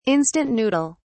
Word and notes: instant noodle: British English

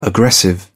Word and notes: aggressive: British English

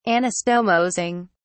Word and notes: anastomosing: British English

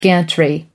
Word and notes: gantry: American English

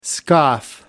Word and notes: scoff: American English

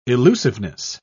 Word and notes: illusiveness: British English

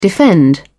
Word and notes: defend: British English

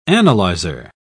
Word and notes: analyser: British English

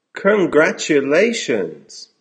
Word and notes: congratulations!: American English

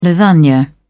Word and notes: lasagne: British English

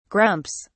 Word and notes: grumps: British English

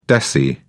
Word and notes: deci: British English

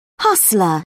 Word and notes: hustler: British English

